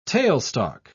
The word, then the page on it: tailstock: British English